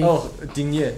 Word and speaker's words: Digne: Oh, Digne.